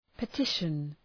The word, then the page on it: petition: pı’tıʃən